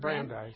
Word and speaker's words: Brandeis: Brandeis. – Brandeis University, his PhD in economics at MIT.